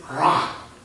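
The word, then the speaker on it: rah (רע): The word evil in Hebrew is rah. I like saying that. Rah. I checked out the pronunciation like so many times because it's like, I don't want to seem like silly. But it's like, this is their word for evil, and it sounds like you're growling.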